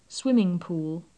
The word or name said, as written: swimming pool